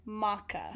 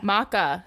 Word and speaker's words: maca: Okay, so now it makes me want to try matcha even more. – Maca. – Maca. How do you spell it? – M-A-C-A.